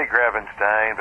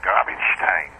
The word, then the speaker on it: Grabenstein: I once was on a panel at a mystery conference and asked a German author, how the heck do I pronounce my name? He went, Grabenstein. So it's sort of like Frankenstein.